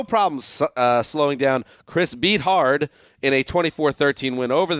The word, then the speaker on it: Beathard: Beathard.